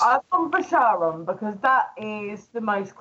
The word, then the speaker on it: Charon: It's actually disputed because it depends on the spelling. Sometimes it's spelled with a H, where it would be Charon, and sometimes it's spelled with a K, where it would be Charon.